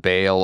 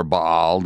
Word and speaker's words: Baal: In the Bible, you often hear of uh, the false god Baal or Baal, depending upon how you want to pronounce it.